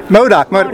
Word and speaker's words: MODOK: And then you did the voice for Matic? how you pronounce it? – MODOK, which is a Marvel series.